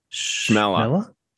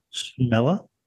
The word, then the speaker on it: Chmelar: And how would you pronounce this? I'm trying to work out how to pronounce this one. It's a surname. C-H-M-E-L-A-R. How do you say that? C-H-M-E. Hey? – Oh, no. – C-H-M-E-L-A-R. – Schmeller. – Schmeller.